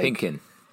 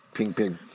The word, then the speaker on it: Kingpin: Like, Pinkin. – Kingpin.